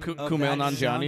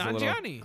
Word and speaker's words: Kumail Nanjiani: Kumail Nanjiani is great. – I did say his name right. Yes. – Well, no. Yeah, well, it was Nanjiani.